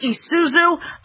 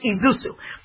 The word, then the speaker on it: Isuzu: It says Isuzu, but it's pronounced Isuzu.